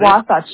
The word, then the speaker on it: Wasatch: Is that Wasatch? Yeah. Wasatch. Yeah. – Wasatch. Okay. Gotcha. All right. – Yeah. – So that's where you're in. So the Wasatch Front.